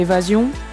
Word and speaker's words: évasion: It looks and reads as Evasion, but she says the French meaning and pronunciation is different. – So Evasion, it was more for the signification in French.